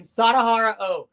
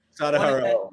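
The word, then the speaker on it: Sadaharu Oh: How do you pronounce his name? What is it? Ho. – Oh, oh, oh yeah, I didn't even say the guy's name. Sadahara O. Sadahara O.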